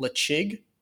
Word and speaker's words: Ledaig: If you wanted to go with something that did not have this luxury brand value, there is a distillery called Lechig, which is spelled L-E-D-A-I-G.